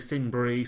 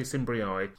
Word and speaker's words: Cimbrii: Some say Cimbri, some say Cimbrii. Just for consistency, we're going to say EI. That's how we're pronouncing this.